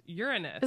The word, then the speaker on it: Uranus: And I learned recently that it's pronounced Uranus.